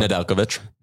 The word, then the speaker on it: Nedeljkovic: Nedeljkovic.